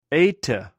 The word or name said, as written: eite